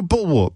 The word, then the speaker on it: bulwark: You know bulwark, B-U-L-W-A-R-K. Is that how you pronounce it? You don't pronounce it like a castrated male bovine. It's bulwark.